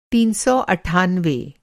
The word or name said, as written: तीन सौ अट्ठानवे